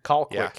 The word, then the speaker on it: Colquitt: Colquitt. Colquitt County is how it's pronounced.